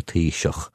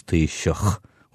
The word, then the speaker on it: Taoiseach: they find it difficult to pronounce the CHs. Like the Prime Minister of our country, the equivalent of our Prime Minister, is called a Taoiseach. And at the end of that, there's a CH, so it's Taoiseach.